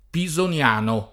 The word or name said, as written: Pisoniano